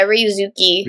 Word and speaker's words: Ryusuke: Yeah, Ryusuke Hamaguchi.